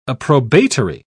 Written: approbatory

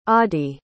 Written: oddie

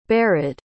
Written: barid

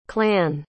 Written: klan

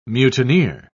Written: mutineer

